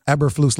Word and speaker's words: Eberflus: How do you say his name? Did you know before he got here? – Eberflus, like they said yesterday on the broadcast.